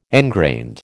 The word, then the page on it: engrained: American English